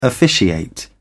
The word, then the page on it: officiate: British English